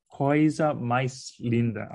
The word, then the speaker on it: Coisa Mais Linda: Coisa Mais Linda. Am I saying that correctly? – Yes, you are.